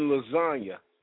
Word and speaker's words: lasagna: That motherfucker said, I'm like the G in lasagna. I'm silent. To most motherfuckers, that don't make sense. But if you listen to the lyrics, I'm like the G in lasagna. The G in lasagna is silent.